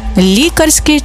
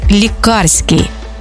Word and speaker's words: лікарський: Лікарський чи лікарський? Можна вживати обидва варіанти, але в різних значеннях. Лікарський – це про лікаря та його діяльність, а лікарський – коли йдеться саме про ліки.